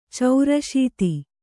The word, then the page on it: ಚೌರಾಶೀತಿ: caurāśīti; Kannada